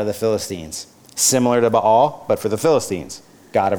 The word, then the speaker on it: Baal: Baal. Now, I say Baal. Don't laugh at me. Some people say Baal. I don't know what the right pronunciation is. I'm correct? Yes, correct. Baal, god of the Canaanites.